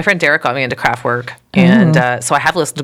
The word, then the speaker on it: Kraftwerk: It's pronounced Kraftwerk.